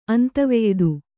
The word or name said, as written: ಅಂತವೆಯ್ದು